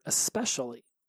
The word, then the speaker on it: especially: Back then, I would pronounce it especially with an X. So it'd be EX instead of ES. So instead of especially, it was especially.